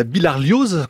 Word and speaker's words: bilharziose: Axel Villard à la une de la science aujourd'hui, donc la bilharziose corse, c'est comme ça qu'on le prononce ? – Bilharziose, oui. C'est une maladie tropicale bien connue, Mathieu, puisqu'en nombre de cas, c'est la deuxième après la malaria.